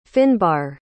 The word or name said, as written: finbar